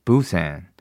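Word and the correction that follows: Busan: 부산을 아 부산을 부산이라고 그 표현한 이유는 제가 그렇게 발음한 이유는 B U S A N이라고 써 있었기 때문입니다, 여러분.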